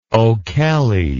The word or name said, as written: o'kelley